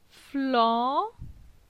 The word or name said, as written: Flan